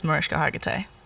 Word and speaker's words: Mariska Hargitay: So I would say my most special meeting with a celebrity was Mariska Hargitay.